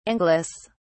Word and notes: anglice: British English

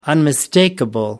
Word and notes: unmistakable: American English

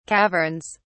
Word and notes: caverns: British English

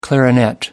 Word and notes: clarinet: American English

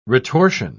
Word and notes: retortion: British English

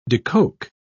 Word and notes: decoke: British English

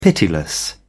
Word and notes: pitiless: British English

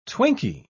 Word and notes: twinkie: British English

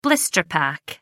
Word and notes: blister pack: American English

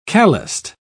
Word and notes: calloused: British English

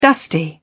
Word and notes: dusty: British English

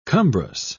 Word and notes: cumbrous: British English

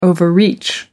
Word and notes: overreach: American English

